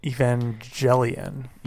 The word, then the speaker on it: Evangelion: No, it's kind of spelled the same as Evangelist, but it's not pronounced the same. – Evangelion.